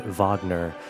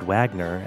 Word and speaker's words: Wagner: This piece, of course, is Ride of the Valkyries by Richard Wagner, not Richard Wagner, as some uncultured swines may pronounce his name.